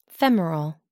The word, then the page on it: femoral: American English